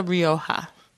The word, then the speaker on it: Rioja: It's a Rioja. – Rioja.